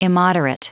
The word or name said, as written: immoderate